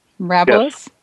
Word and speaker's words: Mirabilis: Mirabilis? – Yes.